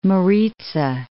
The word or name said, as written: Maritsa